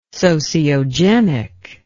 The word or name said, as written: sociogenic